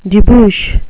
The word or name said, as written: debouch